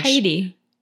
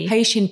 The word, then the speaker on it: Haiti: Is that how you pronounce it in English? When the Haishin, like she had this one person who was charged. – Haiti. – Haitian people. Haiti. – Yeah, sorry. Haiti.